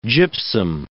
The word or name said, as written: gypsum